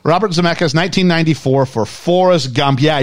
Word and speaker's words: Zemeckis: Can you say his name? And be very careful how you say this. – Zemeckis. – Yes, that's correct.